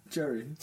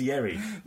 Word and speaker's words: Yerry: Jerry. – That's not his name. – His name is Jerry. – It's Yerry.